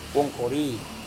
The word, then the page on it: wongkori: Serewen